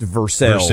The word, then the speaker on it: Versailles: It's like Versailles being pronounced Versailles in Kentucky.